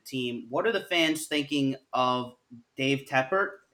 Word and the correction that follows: Tepper: if I'm saying that correctly? – David Tepper, that's right.